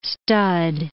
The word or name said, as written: stud